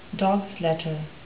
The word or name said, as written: dogs letter